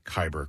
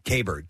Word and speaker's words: Kyber: By the way, it's a Kyber crystal. – Kyber pass. – Yes. – Oh, not Kyber pass. No. How did I pronounce it? – How did I pronounce it? Kaber. K-A-I-B-U-R-R.